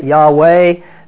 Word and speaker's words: Yahweh: And it is the formula for the personal name of God, which we pronounce Jehovah. You can pronounce it Yahweh.